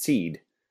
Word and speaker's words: CED: So there's actually a macron over the E, and it's playfully pronounced seed.